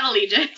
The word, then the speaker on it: Eligius: And this from the girl who, like, literally as we're talking, tweeted at me that I mispronounced Eligius. And now you do! – Isn't it Allegis? – It's Allegis.